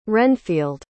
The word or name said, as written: renfield